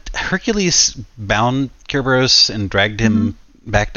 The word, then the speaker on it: Cerberus: I was also curious about the mention of, I know I always say it wrong, Cerberus. – Yes, Cerberus.